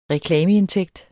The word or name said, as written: reklameindtægt